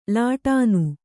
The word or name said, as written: ಲಾಟನ